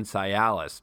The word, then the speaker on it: Cialis: Blue Chew brings you the first chewable with the same FDA-approved active ingredients as Viagra and Cialis.